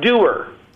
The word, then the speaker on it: Dewar: And by the way, it's pronounced Dewar. James Dewar was the guy who invented it. The same Scottish name as Dewar whiskey, although perhaps not the same family.